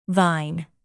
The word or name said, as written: thine